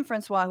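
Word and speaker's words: François: And if all of this sounds head spinning and difficult, leave it to Miriam Francoise, I'm sorry, the S is silent. I do not speak French, but Miriam Francoise, who I believe is a reporter for Al Jazeera English, was on Sky News.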